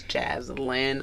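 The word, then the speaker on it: Jazlyn: So my Instagram handle is Jaslyn Amani, for those of you who don't know how to spell that, because my name is not Jasmine, it's Jaslyn. A lot of people get that mixed up. It's no biggie, but it's JazlynNNIMANI. So it's Jaslyn Amani, and that is on Instagram.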